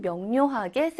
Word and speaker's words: 명료: 자, 우리 명료, 이거를 어떻게 읽냐면, 명료라고 소리 내어서 읽어야 해요. 명료! 막 이렇게 읽지 않고요. 명료하다, 명료하다. 그러니까 편하게 발음을 하다 보니까 이 니은처럼 소리가 나게 되어 있습니다.